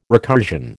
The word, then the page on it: recursion: American English